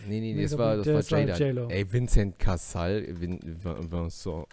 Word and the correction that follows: Cassel: Wie wird's ausgesprochen? Vincent Cassal? – Mhm. – Vincent? Vincent. – Partner. Vincent Cassel. – Cassel. Cassel genau.